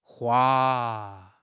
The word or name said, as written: hua1